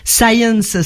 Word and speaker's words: Sayens: Science, ça se dit comme la science en anglais, mais ça s'écrit S-A-Y-E-N-S.